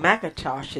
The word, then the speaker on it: McIntosh: Type in Cynthia McIntosh in the search box and hit your enter button. My first name, Cynthia, spelled S-Y-N-T-H-I-A. And my last name, McIntosh, is spelled M-C-I-N-T-O-S-H.